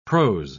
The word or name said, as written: prose